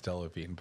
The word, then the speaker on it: Delevingne: I know it's spelled but, uh, Delavigne. I am, I'm an uncultured swine. – I'm fairly certain it's Delavigne, but it's okay.